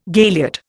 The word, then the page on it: galiot: Indian